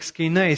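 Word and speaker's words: «скейна» (σκηνή): Или буквально по-гречески оно читается как «скейна», «скиния», «палатка».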